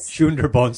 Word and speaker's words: Sundarbans: Am I pronouncing that correctly or not? – Yes, we call it Sundarbans. – Sundarbans.